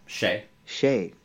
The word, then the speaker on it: Xie: Mukova and Xie, how do you say it? Shea.